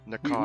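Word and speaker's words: Pnakotic: I have a handbook, and I have the Panacotic manuscripts. I don't know how to say that. – Narcotic? – Narcotic. – We talked about this last time. You don't pronounce the P.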